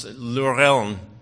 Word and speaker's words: Lorelei: Okay, first, it's a combination of Old German, and it's a word that's pronounced Lorelei.